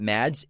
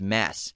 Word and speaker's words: Mads: It's not Mads. It's Mass. – Mass? – He pronounces it Mass. – Oh, wow. – But he doesn't mind if people say Mads.